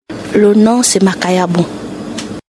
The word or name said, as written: Makayabu